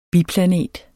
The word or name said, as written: biplanet